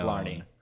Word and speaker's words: Blarney: Blarney.